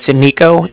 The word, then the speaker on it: Niko: It's Niko. That's N-I-K-O.